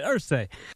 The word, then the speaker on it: Irsay: How do you say Irsay, Irsay? Which one is it? – You know what it is. Irsay.